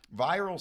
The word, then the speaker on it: VIRL: So viral stands for virtual in real life, so V-I-R-L, but they pronounce it viral, which is kind of a cool acronym.